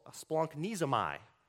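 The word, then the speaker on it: splanknizomai (σπλαγχνίζομαι): It's pronounced splanknizomai. See, that's fun to say.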